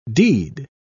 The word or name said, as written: deed